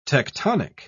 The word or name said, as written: tectonic